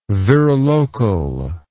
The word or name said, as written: virilocal